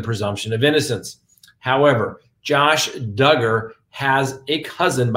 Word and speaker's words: Duggar: So first on the docket, Josh Duggar. That's right. Rhymes with hugger.